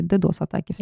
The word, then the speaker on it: DDoS: Сейчас этот замечательный человек наконец-то мне сказал, как правильно читается DDoS-атаки. DDoS-атаки.